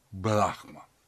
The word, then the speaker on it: Брахма: К этому времени уже различаем, это и Сыркин предлагает делать, Брахма с маленькой буквы с ударением, Брахман с ударением на последний слог, Брахман, средний род, это вот этот безначальный высшее бытие, и Брахма ударением на первый слог, мужской род, это Бог-творец.